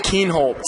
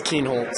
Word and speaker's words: Keenholz: I've heard crazy pronunciations before, and I feel like a lot of them is Keenholz. That's probably the main one I get, yeah. But it is Keenholz.